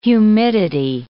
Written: humidity

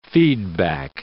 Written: feedback

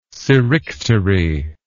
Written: serictery

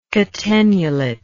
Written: catenulate

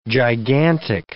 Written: gigantic

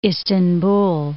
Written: Istanbul

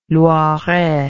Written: loiret

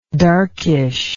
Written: darkishness

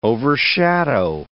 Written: overshadow

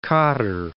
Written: Cape Codder

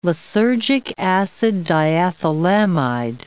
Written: lysergic acid diethylamide

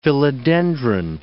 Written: philodendron

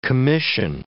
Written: commission

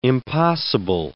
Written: impossible

